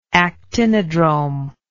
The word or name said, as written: actinodrome